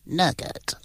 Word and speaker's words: nougat: You don't pronounce a silent T. – No, no, it's nugget.